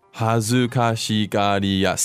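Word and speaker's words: 恥ずかしがりや: Hazukashigariya. Seven syllables. – Oh. – Let's say it one more time really slowly. – Hazukashigariya.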